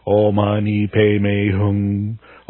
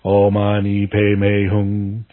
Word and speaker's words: Om Mani Padme Hum: Om Mani Padme Hum, Om Mani Padme Hum, Om Mani Padme Hum, Om Mani Padme Hum.